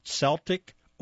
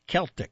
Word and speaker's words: Celtic: It can be pronounced either way. I checked it out, Celtic or Celtic.